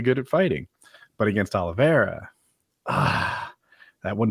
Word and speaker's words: Oliveira: He recently lost to, I struggle with this pronunciation, Oliveira? – That works.